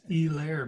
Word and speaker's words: Helaire: So before we do that, is Clyde Edwards-Helaire, do they just not pronounce the H, or is that just a Monday night broadcasting thing? They kept calling him E-Lair. – Yeah, I was like, who the hell's E-Lair? – It's E-Lair, man.